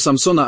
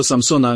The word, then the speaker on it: Samson: Wprowadzenie do Samsona, a teraz Szym, Sean, ilu z was wymawia to słowo Samson? Z literą P w środku? Samsona. Wymawiamy je przez literę P. Czy w Samsonie jest jakieś P? Właściwie to, co robisz, to używasz greckiej wymowy. Grecki ma w sobie P i przeszło na angielski.